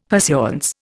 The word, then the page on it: pursuance: Indian